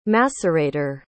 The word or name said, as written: macerator